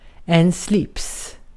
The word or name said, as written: slips